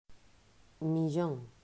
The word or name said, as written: Millon